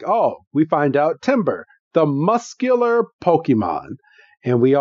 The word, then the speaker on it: Tamburr: And it throws out a Timber. – Yes, a Timber. – If you're keeping track at home, that is spelled T-A-M-B-U-R-R. Tim, basically, Tim is cold. – Yes. Basically, think of a tree falling.